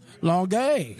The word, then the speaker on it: Longueuil: Longueuil? – Longueuil.